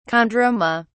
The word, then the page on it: chondroma: British English